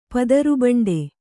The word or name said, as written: ಪದರುಬಂಡೆ